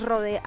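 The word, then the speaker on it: Rhône: ¿Cómo Road? – Rod, es que no sé cómo se pronuncia, Rode, creo que es Rode Alpes. – Ah, ok. Sí. No es Road, sino Rode.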